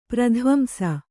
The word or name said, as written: ಪ್ರಧ್ವಂಸ